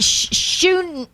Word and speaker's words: Shunem: It was through this woman in Shunem. And Shunem. Yeah, that's how you pronounce it.